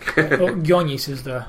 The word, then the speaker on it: Gionis: Gionis is the... – Oh, Gionis, okay. – That's the proper Greek pronunciation.